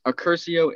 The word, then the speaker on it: Accursio: And then my middle name, which is Accursio. It's spelled A-C-C-U-R-S-I-O. And then my last name is Licata, L-I-C-A-T-A.